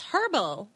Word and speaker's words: herbal: Because I thought that because it's made of herbs like a herb then it's herbal. Like what are you what am I supposed to call it? I've always I've always said the H is silent, like herb. Herbal. Like herbs, herbal.